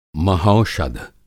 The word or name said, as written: महौषध